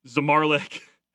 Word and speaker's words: Zamarlik: You got Randy Bednar, Maxwell Costas, Bobby Zamarlik, who had two home runs and seven RBIs. – You got it. It's pronounced just like it's spelled.